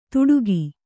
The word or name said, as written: ದುಡುಕಿ